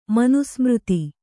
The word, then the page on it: ಮನುಸ್ಮೃತಿ: manu smřti